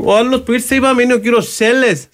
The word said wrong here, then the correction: Σέγες: Σέγες προφέρεται.